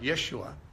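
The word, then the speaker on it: Yeshua (ישוע): You take the name Joshua, actually in the Hebrew it would be pronounced more like Yeshua,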